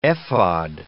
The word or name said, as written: ephod